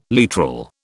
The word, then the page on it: literal: British English